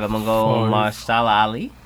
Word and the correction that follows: Mahershala: How you say it again? – I don't know. – Mahershala. – Mahershala. For real? Mahershala.